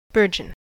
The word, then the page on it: burgeon: American English